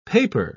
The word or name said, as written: paper